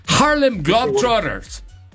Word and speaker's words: Harlem Globetrotters: Pero a ver, ¿cómo se dice? – Los Drop Brothers. – Te tienes que meter a Harmon Hall, ¿eh? Harlem Globetrotters.